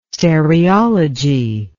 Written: stereology

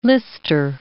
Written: lister

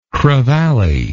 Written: crevalle

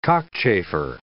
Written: cockchafer

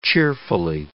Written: cheerfully

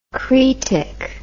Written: cretic

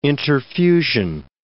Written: interfusion

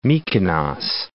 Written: Mikonos

